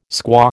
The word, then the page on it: squaw: American English